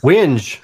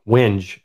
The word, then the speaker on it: Wenge: According to Wikipedia, the word W-E-N-G-E pronounced W-H-E-N-J, Winge. And then if you actually Google W-E-N-G-E, pronounce, it comes up and it says it for you and it says Winge, W-E-N-J.